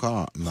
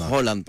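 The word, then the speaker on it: Haaland: Μα δεν είναι Χάλαντ. – Χόλαντ προφέρεται, όπως επίσης δεν είναι Μπέρκεν η πόλη, είναι Μπάρκεν.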